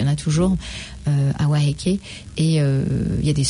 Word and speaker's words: Waiheke: Tout le monde dit prononce mal. Donc c'est Waiheke.